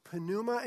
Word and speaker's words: pneuma: You pronounce the P. It's pneuma in Greek and ruach in Hebrew.